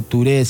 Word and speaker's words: Touré: Es Ali Farka Touré, se pronuncia Touré, se escribe Toure.